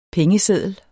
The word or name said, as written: PENGESEDLER